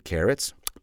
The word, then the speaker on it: carat: But please repeat, C-A-R-A-T. C-A-R-A-T? Yeah, that's pronounced carrot. But of course, it has a completely different meaning. A carat, spelled C-A-R-A-T, is the unit of measurement for the physical weight of a diamond.